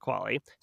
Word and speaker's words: QALY: QALY, pronounced QALY, stands for Quality Adjusted Life Year.